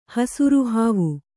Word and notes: ಹಸುರುಹಾವು: kannada

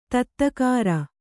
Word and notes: ದಾದಾಗಿರಿ: dādāgiri

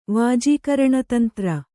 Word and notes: ವಾಜೀಕರಣತಂತ್ರ: kannada

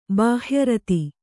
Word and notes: ಬಾಹ್ಯರತ: bāhya rata

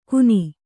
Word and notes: ಗುನಿ: kannada

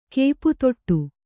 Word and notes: ಕಾಪುತಡೆ: kāputaḍe

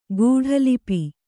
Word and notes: ಗೂಢಲಿಪಿ: guḍha lipi

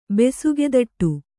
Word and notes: ಬೆಸುಗೆದಟ್ಟು: Kannada

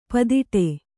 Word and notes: ಪೂದೋಟ: pūdōṭa

